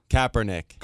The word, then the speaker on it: Kaepernick: Did I say his name right? Colin Kaepernick. – Colin Kaepernick.